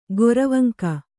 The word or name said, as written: ಗೊರವಂಕ